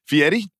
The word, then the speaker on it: Fieri: Fieri? – Yes, thank you.